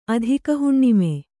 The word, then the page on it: ಅಧಿಕಹುಣ್ಣಿಮೆ: Kannada